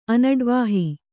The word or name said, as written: ಅನಡ್ವಾಹಿ